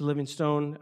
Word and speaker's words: Livingstone: but now they've expanded this year to Livingstone, um, Zambia. And so Livingstone, there's um, it's spelled stone. I always want to say stone, but it's Livingston.